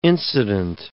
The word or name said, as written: incident